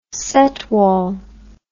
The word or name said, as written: setwall